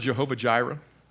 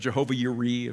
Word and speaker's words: Jehovah Jireh: Have you ever heard the word Jehovah Jireh? Or Jehovah Yireh, if you want to be, phonetically correct.